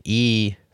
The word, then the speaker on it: e/acc: Nadia, previously Ekbal, now Asparahova, goes deep on AI and the effective accelerationism movement, which is abbreviated E/ACC and pronounced EAC,